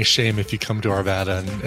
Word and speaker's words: Arvada: To be pronounced Arvada. It's Arvada. – Ah, like Nevada, Nevada. It's the same thing, right?